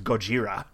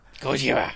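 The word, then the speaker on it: Gojira (ゴジラ): That is the actual phonetic Japanese pronunciation. That's why Godzilla is known as Gojira. – Gojira. – Gojira!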